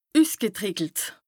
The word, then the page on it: üsgetrìckelt: Bas-Rhin; Strasbourg